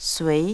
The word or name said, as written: sui